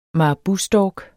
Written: marabustork

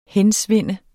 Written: hensvinde

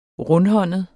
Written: rundhåndet

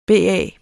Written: BA

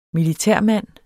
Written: militærmand

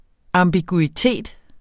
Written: ambiguitet

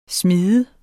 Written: smide

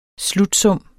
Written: slutsum

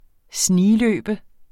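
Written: SNIGLØBE

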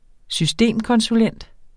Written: systemkonsulent

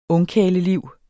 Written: ungkarleliv

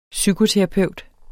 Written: psykoterapeut

